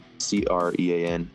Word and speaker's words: Crean: Crean Lutheran High School. It rhymes with Green, C R E A N, out in Irvine, California.